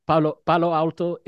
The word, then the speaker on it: Palo Alto: Palo Alto is actually accurately pronounced. – It's being said like in the, okay, perfect. – In English, it actually is the way it's spelled in Spanish.